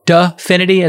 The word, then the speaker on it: Dfinity: Definity is, I think, how it's pronounced, spelt D-F-I-N-I-T-Y.